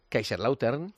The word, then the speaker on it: Kaiserslautern: Que el primer partido es mañana en Kaiserslautern y que el... ¿De qué te ríes? – Partido es un poco Melchor, como lo has pronunciado. – Es que... – ¿Cómo es? – Kaiserslautern.